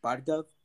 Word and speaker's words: Bhargav: My name is Bhargav. – Bhargav? – Yep.